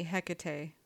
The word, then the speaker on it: Hecate: Well, I've heard it more recently, Hecate.